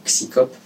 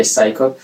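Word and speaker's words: CSICOP: Mais Paul Kurtz qui a créé donc ce qu'on appelait à l'époque le psychop, en prononçant en français le psychop. Ça se prononce en anglais psychop.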